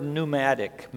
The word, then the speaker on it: pneumatic: What does that word pneumatic mean? Air. How's pneumatic spelled? Starts with a P. If you're not good at English, it's like, well, I didn't hear a P there. Well, that's that silent P.